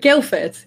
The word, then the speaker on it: Guildford: Guildford, yeah. – Guildford, okay. – Guildford, if you want to pronounce it with the proper Queen's English, Guildford. But no one does.